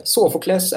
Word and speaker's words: Sofokles: Sofokles är väl det uttalet jag fått lära mig sedan barnsben, men man kan nog uttala det på många olika sätt.